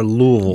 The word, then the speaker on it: Louisville: Is it Louisville or Louisville? – You got to be real guttural down in the throat. It's Louisville. L-U-L-V-U-L. Louisville.